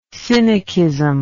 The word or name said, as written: synechism